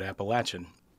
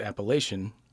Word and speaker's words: Appalachian: Personally, I grew up in Northeast Tennessee, so I call it Appalachian. The man that I hired to do the narration, his name is Dan Gold. He pronounces it Appalachian. In the film, he said that's the preferred.